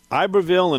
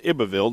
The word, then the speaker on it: Iberville: Today, in 1699, Iberville and Iberville, depending on where you're from, that's how you pronounce it, and Bienville, they were brothers, they located the mouth of the Mississippi.